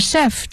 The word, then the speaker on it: chef: 那么 chef 这个单词呢 is spelled as C-H-E-F、那 C-H 在这里面不发 ch 的音，而发 sh，所以是 chef 而不是 chef、这个读音请大家注意。